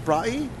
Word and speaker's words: braai: So if I wanted the braai? – Chicken flatbread.